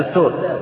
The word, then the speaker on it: دستور: دستور أو دستور، لا أدري النطق كيف، بالباء؟ – لا، بالدال. – بالدال، دستور. دستور.